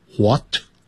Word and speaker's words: what: 这个句子乍看简单其实里面大有学问 What 因为它是放在句子的第一个字所以这个词呢在这边我们叫大写 W 叫大写这个字母我们念过 W What 就是 W-H-A-T 这个是在英式的念法会念成 What 可是在美式念成 What 啊，都可以那个 H 的音就没有了